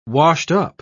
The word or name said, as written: washed-up